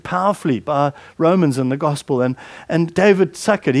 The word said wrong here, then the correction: Suchet: he's an actor. I don't know, is that how you pronounce his name? Suchet. David Suchet.